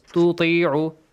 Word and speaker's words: تطيعوا: It might be difficult to pronounce the two letters. تطيعوا. تطيعوا.